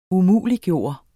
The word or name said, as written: umuliggøre